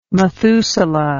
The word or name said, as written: Mathusala